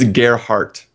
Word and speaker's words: Gerhardt: I know how an American would and a German would pronounce my last name. It's Gerhardt. – The D is like, you know, silent.